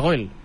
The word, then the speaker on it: Goyle: Llavors quedava goyle, que es pronuncia goyle, però no, la gent anava a dir goyle, i llavors vam treure la e del final.